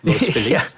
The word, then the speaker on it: Los Feliz: People will correct you if you say Los Feliz. – Yeah. Although that's the correct pronunciation for Spanish. Los Feliz.